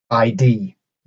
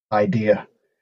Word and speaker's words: idea: Or consistently mispronouncing a word like ID instead of idea.